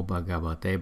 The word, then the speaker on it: Bhagavate: Trong tất cả các câu này đều có chữ Namo Bhagavate bắt đầu. Và chúng ta sẽ để ý những chỗ tô màu đỏ để chúng ta dễ học. Chữ BHA chúng ta đọc là Bha, Namo Bhagavate, Namo Bhagavate, Namo Bhagavate.